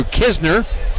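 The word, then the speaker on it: Knizner: Andrew Kisner, we know how to say his name, even though it's K-N-I-Z-N-E-R.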